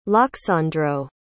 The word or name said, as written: lyaksandro